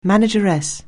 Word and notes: manageress: anglais

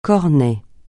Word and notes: cornet: francais

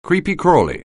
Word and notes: creepy-crawly: anglais

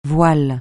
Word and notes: voile: francais

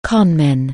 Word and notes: conman: anglais

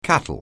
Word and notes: cattle: anglais

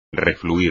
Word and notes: refluir: espagnol

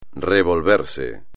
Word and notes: revolverse: espagnol

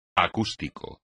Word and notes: acústico: espagnol

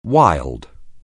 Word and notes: wild: anglais

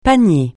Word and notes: panier: francais

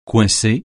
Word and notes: coincer: francais

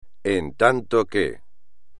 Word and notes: en tanto (que): espagnol

